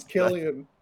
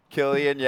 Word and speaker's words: Cillian: Well, I think it's Killian. – Killian, yeah.